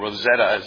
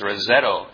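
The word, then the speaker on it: Roseto: And it's spelled with an O at the end, so I don't know, Rosetta is what they called it, but it's Rosetto.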